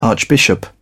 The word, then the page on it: archbishop: British English